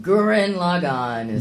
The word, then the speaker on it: Gurren Lagann: Do you say, how do you pronounce it? – Gurren Lagann. – Gurren Lagann. – With extra R's and...